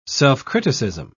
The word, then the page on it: self-criticism: British English